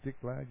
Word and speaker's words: Ziklag: And David went and stayed at a place called Ziklag. And is that how you pronounce it? Ziklag, yeah.